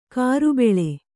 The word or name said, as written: ಕುರಿಬಳ್ಳಿ